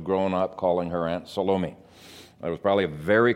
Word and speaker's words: Salome: As I've already mentioned, Salome is normally pronounced Salome. Some people pronounce her name Salome, and the Greek actually is Salomein with an N on the end. I think all three pronunciations have the emphasis on the second syllable, but the standard really is Salome, at least in the English language.